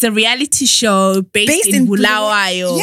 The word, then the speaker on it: Bulawayo: Bulawayo.